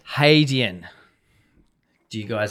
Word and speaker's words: Hadean: If you look it up online, that's how it sort of identifies to pronounce it, Hadean. – Yeah, so hay, not had. Had. Hadean.